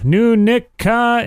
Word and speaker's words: Nunica: Nunica is how it's pronounced.